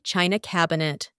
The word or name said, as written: china cabinet